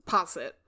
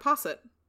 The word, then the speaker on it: posset: Okay, I think posset is right. – You were pretty close. – Well, that's how I said it, posset.